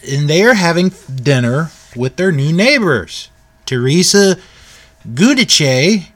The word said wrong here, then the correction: Giudice: I think I pronounced that right, Giudice, pardon me, and her entire family.